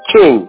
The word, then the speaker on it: King: You spell it T-A-R-R-A-N-C-E. King. K-I-N-G.